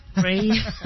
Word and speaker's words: Raye: Ray. Bueno, se escribe Raye, pero no sé cómo se escribe.